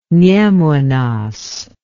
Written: nemunas

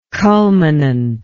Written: culminant

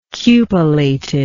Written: cupolated

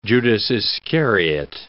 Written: Judas Iscariot

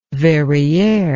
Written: verriere

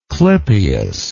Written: clypeal

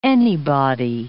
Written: anybody

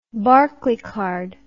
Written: barclaycard

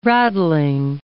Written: raddling

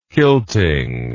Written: kilting

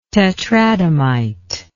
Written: tetradymite

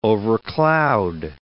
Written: overcloud

